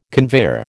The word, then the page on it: conveyor: American English